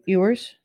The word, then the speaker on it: Ewers: How do you say Ewers? – Ewers.